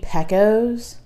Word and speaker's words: Pecos: And the guy he works for is like, you mean Pecos? Because it's P-E-C-O-S. – Yeah. – He's like, you know it's pronounced Pecos and the Rooftops, right?